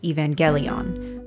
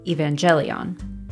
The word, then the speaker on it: Evangelion: You may have heard it pronounced with a hard G, Neon Genesis Evangelion, rather than a soft G, like Brandon says, Evangelion.